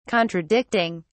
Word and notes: contradicting: British English

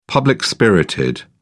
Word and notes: public-spirited: British English

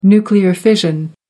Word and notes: nuclear fission: American English

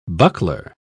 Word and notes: buckler: British English

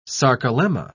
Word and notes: sarcolemma: British English

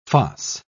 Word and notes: foss: British English

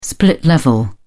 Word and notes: split level: British English

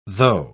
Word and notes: tho': British English

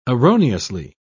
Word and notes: erroneously: British English

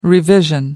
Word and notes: revision: American English

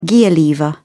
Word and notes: gear lever: British English

